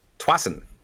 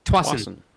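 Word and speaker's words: Tsawwassen: is it Tawasin? – Tawasin. – Tawasin. Really? Is this Tawasin? So many letters that don't even get used. – Only taking me 3 weeks. – And there's a silent Q in there too. – If you watch the time-lapse video, you'll see this spelled out, but it's T-S-A-W-W-A-S-S-E-N, and it's just Tawasin.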